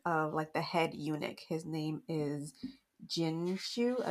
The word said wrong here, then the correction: Jinshi: I always pronounce it wrong. Jinshi. Wow. His name is Jinshi.